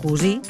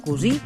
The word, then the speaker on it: cosir: Ens atem aquesta setmana amb termes relacionats amb les tasques de la vida quotidiana, com ara cosir, pronunciat cosi o cosí, segons la procedència del parlant, i dels termes relacionats.